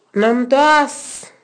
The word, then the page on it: Namtoas: Naka